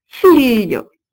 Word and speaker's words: figlio: Le L palatal, lieu, se fait entendre, par exemple, aujourd'hui, dans l'italien figlio, F-I-G-L-I-O, qui signifie fils, et ce lieu se prononce comme un L avec la langue largement étalée contre le palais.